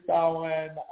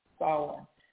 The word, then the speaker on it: Samhain: There's a number of people who celebrate Samhain as Samhain. Looks like it should be pronounced, and I've heard it pronounced that way, as Samhain, S-A-M-H-A-I-N, but it's an old Celtic word, and some say it means summer's end.